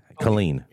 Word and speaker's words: Killeen: Killeen or what is it? Killeen. – Yeah. – Yes, clean.